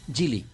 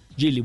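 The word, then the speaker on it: Geely: Eh, ¿cómo, se pronuncia, ¿Cómo lo pronuncia usted? ¿Gili o Jelly? Gili. Gili, bueno, Gili con es la nueva subhíbrida.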